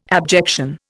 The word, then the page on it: abjection: Indian